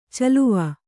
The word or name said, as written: ಚೆಲುವು